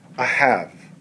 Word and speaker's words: ahav: And it's spelled A-H-A-Y. And you would say it this way, ahav. a have.